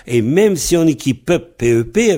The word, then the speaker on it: pep: Voilà, alors chaque c'est pep. Ça se prononce pep, P-E-P, pep. Mais quand il se trouve devant un mot qui commence par une voyelle, si ça commence par une voyelle, le mot qui suit, ça devient pub, P-E-B.